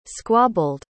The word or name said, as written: squabbled